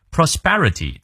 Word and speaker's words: prosperity: Prosperity，注意它的重音在后。